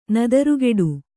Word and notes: ನೆತ್ತರುಗೊಡೆ: Kannada

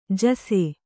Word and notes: ಜೈಸು: Kannada

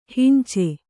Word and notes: ಹಂಚು: Kannada

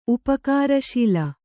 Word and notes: ಉಪಕಾರಶೀಲೆ: upakāraśile

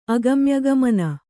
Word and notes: ಅಗಮ್ಯಗಮನ: kannada

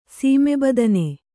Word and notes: ಸೀಮೆಬದನೆ: sīme badane

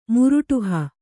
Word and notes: ಮುರುಟುಹ: kannada

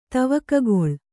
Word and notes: ತವಕಗೊಳು: Kannada

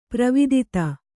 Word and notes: ಪೂರ್ವೋದಿತ: pūrvōdita

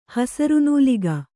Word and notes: ಹಸುರುನೂಲಿಗೆ: hasuru nūlige

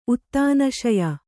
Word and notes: ಉತ್ತಾನಶಯ: uttānaśaya

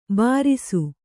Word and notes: ಭೃಶ: Kannada

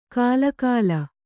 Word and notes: ಕಾಲಕಾಲ: kālakāla

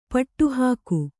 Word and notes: ಪಟ್ಟುಹಾಕು: Kannada

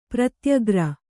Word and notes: ಪ್ರತ್ಯಾಕಾರ: Kannada

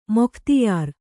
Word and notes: ಮೊಖ್ತ್ಯಾರ: Kannada

